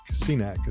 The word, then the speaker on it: SINAC: Don't know if I'm pronouncing that right, but it's a SINAC. SINAC is what it's called, S I N A C.